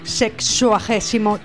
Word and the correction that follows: sexagésimo: tercer programa. – ¿Sexagésimo tercer programa? – Sí.